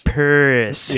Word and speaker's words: Perris: They spell it different out there. – Yeah. P-E-R-R-I-S. – Paris.